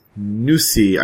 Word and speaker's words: Nusii: And so your product is Nusi.